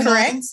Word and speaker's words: Tretinoin: Tretinoin. – Yep. Mm-hmm. Correct. Tretinoin.